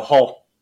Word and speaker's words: Hull: Not hell, Hull. – Yeah, that's what I said. Hull. – Oh, I thought you said in hell, and I was like, well, not yet. – Hull.